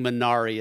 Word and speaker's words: Minari: Yeah, I think Minari is generally accepted as being the okay pronunciation and the whatever the Korean pronunciation is.